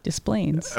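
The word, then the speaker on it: Des Plaines: How do you pronounce Desplaines? – Desplaines. – Okay. You just mispronounced that name.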